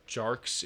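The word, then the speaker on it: Tjarks: I think it's like Jarks. It's T-J-A-R-K-S.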